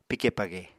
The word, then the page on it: pike page: Apatani